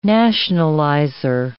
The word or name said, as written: nationalizer